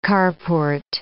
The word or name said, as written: carport